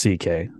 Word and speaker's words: C.K.: It's pronounced CK. – Is that really how it's pronounced? The whole thing? – Okay. That's why he calls himself Louis. – That's C.K. All right.